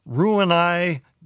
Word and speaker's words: Roux-en-Y: She writes, "Dear Dr. Zorba, after a lifetime of being overweight, I recently had Roux-en-Y gastric bypass surgery."